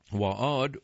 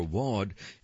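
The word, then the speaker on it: Waad al-Kateab: Waad, or Wad, is spelled W-A-A-D, and then her last name is spelled lowercase A-L hyphen uppercase K-A-T-E-A-B. Waad al-Kataib.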